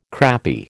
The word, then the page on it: crappy: American English